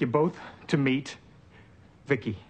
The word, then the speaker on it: Vicki: V-I-C-I, I pronounce it Vicki.